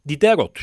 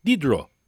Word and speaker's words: Diderot: Okunuşu Diderot, ancak Fransızca telaffuz kaidelerine aşina olmayanlarımızın onu Didero veya Diderot diye biliyor olmaları da mümkündür. Biz Fransızca okunuşa sadık kalarak Didro diye anacağız kendisini.